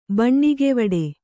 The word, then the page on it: ಬಣ್ಣಿಗೆವಡೆ: Kannada